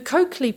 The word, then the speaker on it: Colclough: The Coakley pronunciation was first heard through a chance encounter between one of my brothers and a work colleague.